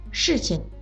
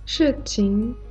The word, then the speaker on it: 事情: 事情的“ 事” 是四声，情是轻声，事情；实情的“ 实” 是二声，情也是二声，实情。大家说的时候一定要注意声调。 – 事情、实情，对吧，猪猪？ – 对，非常好。